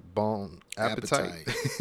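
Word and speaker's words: Bon appétit: Bon Appetit, but it's got the e at the end, so it's like Bon Appetite. – appetite. but it is pronounced Bon Appetit.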